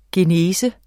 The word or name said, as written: genese